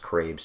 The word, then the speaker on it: Crabe: The cinematographer shooting it, James Crabe, or Crabbe, I think it's just pronounced Crabe, C-R-A-B-E.